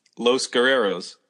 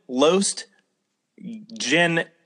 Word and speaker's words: Los Guerreros: He mispronounced the name of a tag team, Los Guerreros. He called them Los Generos. He literally said Los Generos.